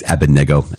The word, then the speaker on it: Abednego: I always go back and forth with Abednego and Abednego. It's Abednego, I hear, because I believe the accent is on the third syllable.